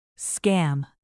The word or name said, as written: scam